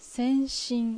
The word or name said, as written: Senshin